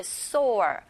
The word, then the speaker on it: soar: 이 soar 자체가 치솟다라는 soar 발음하실 때 soar 이렇게 발음하시고 얘랑 비슷하게 생긴 단어 철자 다르지만 구분하셔서 알아두셔야 되겠죠. 요건 sour, S-O-U-R, sour, 맛이 신이란 뜻으로 구분해서 알아두시기 바랍니다.